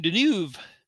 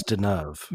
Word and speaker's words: Deneuve: And so anyway, Unflick from 1972 stars Alain Delon and Richard Crenna and Catherine Deneuve. And that's one that I'm not familiar with. That's a Kino release, I believe. – It's Deneuve, by the way. – I'm butchering them all.